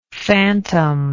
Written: fantom

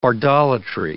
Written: bardolatry

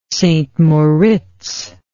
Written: St Moritz

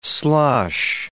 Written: slosh

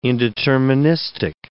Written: indeterministic

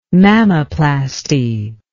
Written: mammoplasty

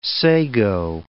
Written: sago

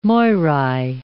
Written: Moirai

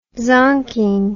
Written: zonking

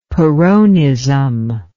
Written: peronism

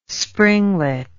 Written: springlet

